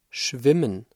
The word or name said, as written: schwimmen